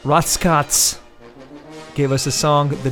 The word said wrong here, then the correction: Rotzkotz: Did I pronounce that right? – Rotzkotz. – Yeah, Rotzkotz. That's how the English, that's how we say it in America.